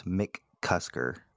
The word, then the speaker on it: McCusker: So Matt McCusker, that's Mick Cusker. It's spelled as if you would say it.